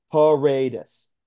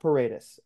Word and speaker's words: Paredes: So what it says on the Rays official pronunciation guide on the MLB website is Isaac, excuse me, Paredes. So Isaac Paredes, apparently.